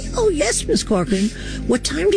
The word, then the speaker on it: Corcoran: I can never say her last name right. Barbara Corcoran? – Corcoran? – Yeah, that's exactly how you say it.